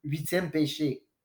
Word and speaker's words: Huitième Péché: And how do you pronounce that brewery? I didn't want to fuck it up earlier, so. – Huitième Péché.